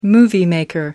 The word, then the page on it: moviemaker: American English